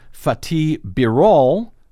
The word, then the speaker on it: Fatih Birol: IEA Executive Director Fatih Birol, did I say that right? – Yes, you did.